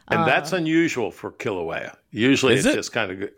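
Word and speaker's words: Kīlauea: Hawaii's Kilauea volcano shoots lava 330 feet into the sky. – Kilauea volcano yep.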